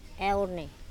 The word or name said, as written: Eone